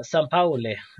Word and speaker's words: Sampaoli: Eh, där han ställs då mot Pep Guardiola som vi också är ganska bekant med och eh, Jorge Sampo, Sampaoli, ursäkta uttalet där.